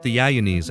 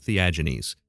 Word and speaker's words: Theagenes: The name would be pronounced Theagenes, I think, in Greece, but for the purpose of this podcast, I'll be pronouncing it Theagenes.